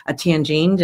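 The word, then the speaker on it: tagine: And we had a recipe in today's paper for a tangine. Did I say that properly? – Yes, you did.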